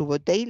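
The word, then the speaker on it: Subotai: Fíjese la promesa de, su, eh, creo que lo he dicho mal, de su botella a Gengis Khan cuando se unió a él. Su Subotei le prometió esto,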